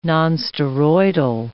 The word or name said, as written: nonsteroidal